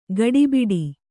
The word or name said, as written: ಗಡಬಡ